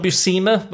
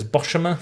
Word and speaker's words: Buscema: Um, John Buscema, but, but I used to pronounce it as Boshima for some reason.